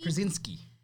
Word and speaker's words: Krasinski: So I don't know how many of you guys are watching Some Good News, the John, how do you say his last name? Krasinski? – Krasinski.